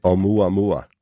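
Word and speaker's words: Oumuamua (ʻOumuamua): Oumuamua.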